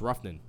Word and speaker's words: Rougned: Ru- it's I believe it's Ruffin.